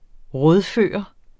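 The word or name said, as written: RÅDFØRT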